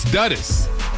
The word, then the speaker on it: Dudas: It's Dudas. – Dudas? Oh, so because the person who just, Dudas. Ian just wrote me, and Ian said, his name is pronounced Dudas like rhyming cud or bud.